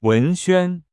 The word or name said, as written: 贾文轩